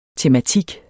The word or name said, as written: tematik